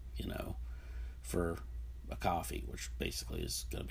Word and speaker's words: Ko-fi: You know, for my blog, I've got a Ko-fi account, or I believe it's actually pronounced coffee,